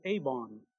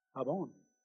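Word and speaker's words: Avon (עָוֹן): It's the word, we pronounce it Avon. Avon.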